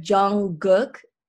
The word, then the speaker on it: Jungkook: Well, the way I say it, it's so weird because the way they spell it in English is different. It's like Jungkook, which is weird. It's like Gook. Oh, here, maybe it'll help you like visually. It's J-U-N-G-G-U-K, Jungkook.